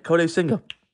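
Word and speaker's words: Kodai Senga: I got his name right. Kode Senga.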